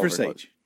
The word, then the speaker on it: Versace: It's pronounced Versace.